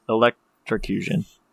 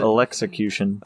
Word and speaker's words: electrocution: Moderate electrocution. Electrocution. – What? Got it. I said it wrong. – Oh, okay. I didn't catch it. – Electrocution.